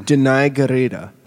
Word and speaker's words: Danai Gurira: Denai Garita. That is, in fact, how you pronounce it.